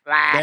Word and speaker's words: Aflac: You got to say it like the duck. – No, we won't do that. – Aflac.